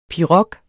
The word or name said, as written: pirog